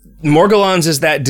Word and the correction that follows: Morgellons: It's Morgellons.